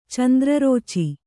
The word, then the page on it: ಚಂದ್ರರೋಚಿ: Kannada